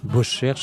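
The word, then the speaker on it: Bosschère: Beauchère, ça s'écrit B-O-S-C-H-E, accent grave R-E.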